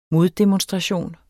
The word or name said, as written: moddemonstration